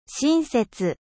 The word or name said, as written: gentil